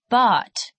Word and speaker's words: bought: Buy, bought. Teach, taught. Think, thought. The GH is silent.